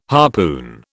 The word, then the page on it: harpoon: British English